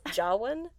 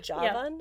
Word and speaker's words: Jawan: we have been pronouncing the name of Shah Rukh's new movie incorrectly, and that it is yes. – Jawan and not Javan.